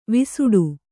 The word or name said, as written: ವಿಸಟ